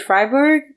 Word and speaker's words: Freiburg: But I mean, how do you feel like that experience in, you said Freiburg?